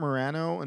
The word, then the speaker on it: Maragno: I say it Marano, and then I learned Italian, well, a little bit of Italian, and it's like lasagna. That Z makes it Y sound.